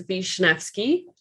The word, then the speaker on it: Wisniewski: So my last name is Wisniewski. So Polish, and you have to blame my husband for that one. So I'm always telling someone how to say my name.